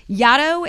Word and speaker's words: Yaddo: Yaddo is a neologism invented by one of the Trask children and was meant to rhyme with Shadow.